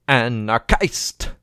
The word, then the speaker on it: Anarchist: I am an Anarchist! Because it has to rhyme with Christ.